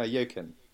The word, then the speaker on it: Joaquin: No, Joaquin. It's like Joaquin Phoenix. – Joaquin.